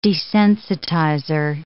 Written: desensitizer

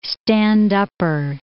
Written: stand-upper

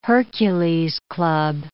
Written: Hercules'-club

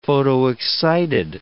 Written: photoexcited

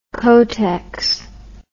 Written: kotex

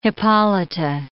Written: Hippolyta

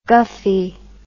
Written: guffy

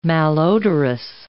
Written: malodorous

